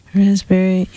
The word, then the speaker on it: raspberry: K-N-E-E-L, Neil. – Raspberry answer. – Silent letters. – Yes, silent letters. Oh, yeah. Raspberry is the silent S. – P. – Or P. Sorry. – Silent P, right?